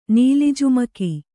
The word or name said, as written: ನೀಲಿಜುಮಕಿ